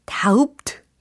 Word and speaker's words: doubt: 어, 아주 자신감 넘치게 d o u b t 라고 하셨는데 그러면 안 돼요. 자, 여기에서 B라는 철자는 철자에만 있을 뿐 소리는 무음입니다. 발음이 되지 않아요. 그러니까 B는 철자에만 보일 뿐 발음이 안 된다?